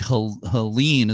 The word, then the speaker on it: Helena: So again, St. Helene, as you're pronouncing it, is actually spelled H-E-L-E-N-A.